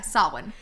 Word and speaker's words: Samhain: However, it rhymes with champagne, so you better fucking believe one year I'm having champagne Samhain, it's going to be fucking litty. – Oh, yeah. – But anyway, yeah, Samhain.